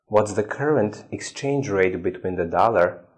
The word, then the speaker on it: exchange rate: So the stress is on the first word exchange rate.